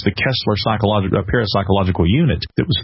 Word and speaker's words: Koestler: He kind of played down the fact that later in his life, Arthur Kessler, or Kessler as it would have been pronounced in the German, he was actively involved in parapsychological research.